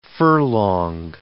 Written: furlong

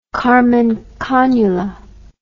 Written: karman cannula

